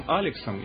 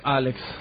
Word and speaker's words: Алекс: Как правильно твое сокращенное имя произносится? Тебя зовут там Алексом или Сашей? – Алекс. – Алекс. – То есть... Ну, мама, папа мой, ну... – То есть все Алекс? – Семья моя, да, все Саша, конечно.